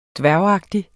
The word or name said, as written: dværgagtig